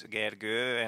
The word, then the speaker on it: Gergő: My name also has like two lines on top of the O. It's Gergő, and most of the people I talk to can't really pronounce it.